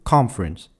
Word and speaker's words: conference: Mais cela s'accentue sur la première syllabe en anglais. Ainsi, conférence ne donnera pas conférence en anglais, mais plutôt conference.